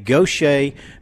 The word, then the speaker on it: Gautier: If you're on I-10, it's spelled G-A-U-T-I-E-R. So, Kenny, it is not pronounced the way it is spelled.